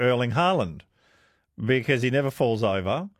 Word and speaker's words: Erling Haaland: Erling Haaland is pronounced in Norwegian like, it's Erling Haaland.